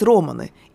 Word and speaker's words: романы: У этого процесса было даже специальное название – «толкать романы», именно так, с ударением на «о».